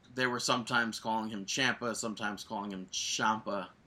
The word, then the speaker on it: Ciampa: Wait, what was his last name? – Ciampa. – So the entire CWC and every commentator is saying it incorrectly, even though in a promo he corrected all of them.